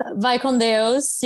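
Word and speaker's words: Vai com Deus: And, you know, vaya con Dios, but that's not how you say it in Portuguese. Is it just vaya con Dios? – Vai con Deus. Yes.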